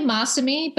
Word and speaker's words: Masami: It's actually Masami, but Masami. You wouldn't know that. And everyone pronounces it that way. But the reason we say Masami is because it's named after my co-founder's husband, Masa.